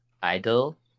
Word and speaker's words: (G)I-DLE: All right, my group is Idol. The G is silent.